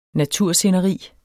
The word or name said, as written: natursceneri